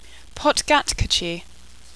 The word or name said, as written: Potgatkerchi